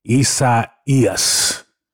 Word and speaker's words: Isaías: And therefore, you know, all of us want to say Isaiah because we are mindful of the book of Isaiah in the Old Testament. But the Spanish people pronounce it Isaías.